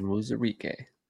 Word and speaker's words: Onwuzurike: You got Levi Onwuzurike that you, not, yep. you screwed me up last time. – Onwuzurike. Yeah. – He always likes to add syllables. Levi Onwuzurike.